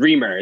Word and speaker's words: Reimer: Reamer is how you pronounce it.